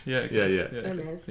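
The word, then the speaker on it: Hermès: There's no H for Hermes. It's a silent H. – Hermes.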